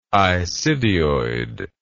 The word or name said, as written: isidioid